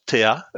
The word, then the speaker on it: Theia: on le prononce Theia. Que je sache, il n'y a pas vraiment de façon bonne ou mauvaise de le prononcer.